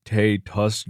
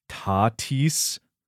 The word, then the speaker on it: Tatis: Also, you mispronounced one of the biggest stars' names in an episode, calling him Fernando Tatis Jr. when it's pronounced Tatis.